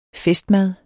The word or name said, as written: festmad